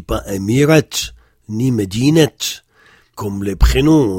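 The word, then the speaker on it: Medinet (مدينة): Dans ce cas, il faut prononcer et. Par exemple, si je dis la ville de Paris, je dis Medinet Paris.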